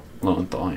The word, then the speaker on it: Lowenthal: Lowenthal, yeah.